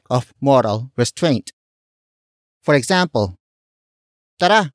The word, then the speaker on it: tara: Word for word it's tara, walwal.